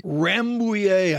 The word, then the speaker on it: Rambouillet: Rambouillet, I think I pronounced that right.